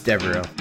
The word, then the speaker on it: Devereaux: It's Devereaux. – Devereaux. – There is no N in the name.